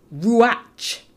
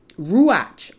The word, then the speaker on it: ruach: Now, in Hebrew, the term for spirit is ruach, ruach, R-U-A-C-H, a word pronounced in such a way to emphasize the H sound.